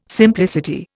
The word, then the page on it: simplicity: Indian